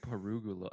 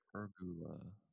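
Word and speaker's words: pergola: Dude, I like just learned how to say this because I kept on saying perugula. let me see. Pergula. Yeah, P-E-R-G-U-L-A. – Okay, P-E-R-G-U-L-A.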